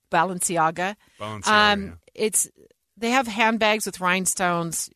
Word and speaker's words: Balenciaga: But unless you are into or can afford high-end luxury fashion, you've probably, are like me, and never heard of Balenciaga. It's a brand. Did I say that right? I don't even know. – No. – How is it said? – Balenciaga. – Balenciaga. Yep.